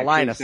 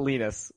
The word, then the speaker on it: Linus: That is the actual Linus, right? That's how Linus, said. Not Linus.